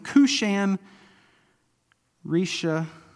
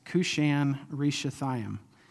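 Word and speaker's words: Cushan-Rishathaim: Verse 8 says, then the anger of the Lord was kindled against Israel, and He sold them into the hands of Cushan Rishathaim. Okay, that's the only time that I'm going to pronounce that correctly because I have it transliterated here in my notes. Cushan Rishathaim.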